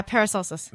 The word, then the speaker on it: Paracelsus: Uh, P R P A R A C E L S U S. – Yeah, Paracelsus.